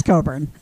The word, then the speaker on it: Cockburn: Bruce Coburn, which is spelled Cockburn, but it's Coburn.